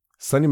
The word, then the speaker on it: Sony: Sony is Sonny spelled like Sony, and Michelle is spelled, you know, different too.